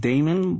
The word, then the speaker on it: Damon: My name, of course, is spelled Damon, but Raymond with a D.